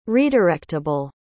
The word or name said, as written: redirectable